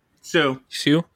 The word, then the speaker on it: Hsu: Her last name. HSU. How do you say it? – Yeah. Sue.